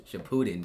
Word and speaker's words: Shippuden: Shippuden is wild because it's pronounced Shippuden. – Oh, it is Shippuden.